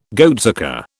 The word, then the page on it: goatsucker: British English